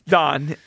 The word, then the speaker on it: Don: That's why I can't get mad at Dom Mattingly. – No, see? – No, again. It's Don, and you also know his name. You just mispronounced.